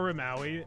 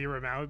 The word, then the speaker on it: Rimawi: My name is Omar Rimawi. Omar B. Rimawi, because someone beat me to, you know, the name without the middle initial.